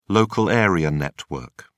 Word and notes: local area network: British English

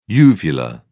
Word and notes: uvular: British English

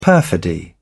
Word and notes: perfidy: British English

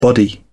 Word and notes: body: British English

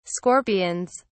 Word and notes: scorpions: British English

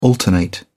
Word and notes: alternate: British English

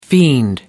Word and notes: fiend: American English

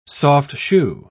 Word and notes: soft-shoe: British English

